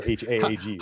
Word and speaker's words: Haag: Um, uh, Hag or Haig or I don't know. The, the, double A thing always throws me. Like her name is spelled H A A G. Haag. Right? yeah.